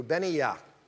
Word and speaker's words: Benaiah: His name is Benaiah, is how you pronounce it in Hebrew. Benaiah.